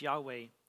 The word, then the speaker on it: Yahweh: It would be pronounced Yahweh in Hebrew.